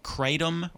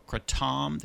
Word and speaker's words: kratom: And it was a good 45 minutes that we talked about a few things, but generally CBD, hemp oil, kratom or kratom, there's two different ways to pronounce that.